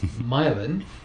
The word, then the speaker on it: Milan: That's spelled Milan, but you know the Americans and the English. – Yeah. – They say Milan, and that is the birthplace of Thomas Edison.